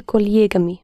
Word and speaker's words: коллегами: But the stress is on this stem, not on the ending. – С коллегами. – Yes, the same as коллега. С коллегами.